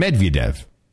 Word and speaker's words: Medvedev: Medvedev.